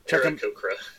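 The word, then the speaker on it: Aarakocra: Just as a heads up for the listeners, if we say Birdman at any point, we are talking about Arakoa. Or Arakoa, or however they're properly pronounced. – Arakocra. – Arakocra. Okay, that might be the only time it's properly said.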